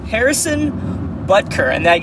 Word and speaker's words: Harrison Butker: And then the other, our kicker this week, Harrison Butker. Said that right.